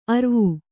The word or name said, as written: ಅರ್ವು